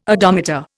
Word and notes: odometer: Indian

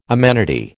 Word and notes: amenity: American English

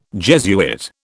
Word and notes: Jesuit: British English

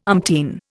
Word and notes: umpteen: Indian

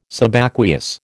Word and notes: Subaqueous: American English

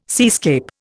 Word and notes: seascape: Indian

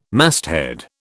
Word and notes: masthead: British English

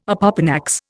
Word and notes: opopanax: Indian

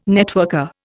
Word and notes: networker: Indian

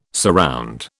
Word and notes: surround: British English

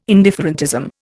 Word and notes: indifferentism: Indian

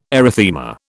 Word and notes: erythema: British English

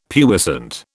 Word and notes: puissant: British English

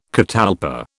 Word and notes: catalpa: British English

